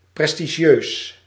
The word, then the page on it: prestigieus: /pɾɛs.ti.ɣiø:s/